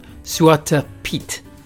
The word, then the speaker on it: Zwarte Piet: Boys and girls, last episode I discussed the festival of Sinterklaas in the Netherlands, and I touched briefly upon the gentleman Zwarte Piet. That's how you pronounce it.